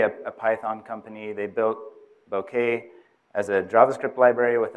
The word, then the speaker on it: Bokeh: So Arbokeh, you can pronounce it Arbokeh as well, but the developers of Bokeh call it Bokeh, so that's what I call it.